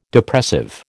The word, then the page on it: Depressive: American English